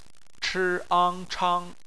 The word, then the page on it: chang: bpmf